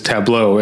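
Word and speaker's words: tableaux: The plural of tableau is tableau, except with an X at the end, but it's silent. So it's pronounced the same way.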